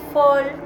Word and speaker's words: fall: Y el de abajo es fall, que se escribe con A, pero se dice con O.